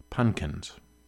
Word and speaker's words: Punkins: This is called Jumpin' Pumpkins. Pardon me, he calls it Jumpin' Punkins, which I think is how you do pronounce that word.